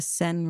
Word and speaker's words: Seine: The Seine River.